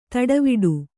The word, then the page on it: ತಟವಟ: Kannada